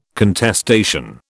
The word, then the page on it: contestation: British English